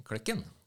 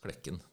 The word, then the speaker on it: Klekken: Jeg har en sånn kjepphest at det er så mange som uttaler det for eh, Klekken, men det uttales Klekken. Det er et såkalt tonelag. Det er omtrent forskjellen som eh, bønner og bønder. – Ja. Altså ikke Klekken, men Klekken.